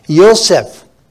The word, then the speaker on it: Yosef: Well, Brother Joel, right back there, Yosef. That's it, the way they say his name, Yosef. They put I, but actually it's supposed to be with a Y, Yosef.